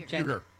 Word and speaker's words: Uygur: Uygur.